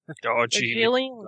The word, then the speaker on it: Darjeeling: Darjeeling.